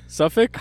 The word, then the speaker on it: Suffolk: Suffolk? – How is it spelled? F-O-L-K? – S-O-F-F-O-L-K.